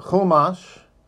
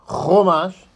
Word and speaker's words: Chumash: It's a chumash, which is pronounced chumash, not chumash.